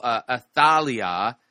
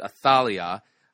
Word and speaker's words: Athalia: Athalia, that's how you pronounce it, Athalia.